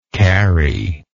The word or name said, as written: carie